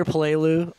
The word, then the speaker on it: Peleliu: Rupert has broke the back of the Japanese in World War II from Guadalcanal to Peleliu. – We're gonna just for our listeners, when you hear Peleliu or Peleliu, we're talking about the same place.